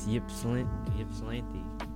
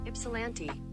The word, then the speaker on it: Ypsilanti: I think it's Yipslanty. – Oh my god. How to say? How to pronounce? – Ypsilanti.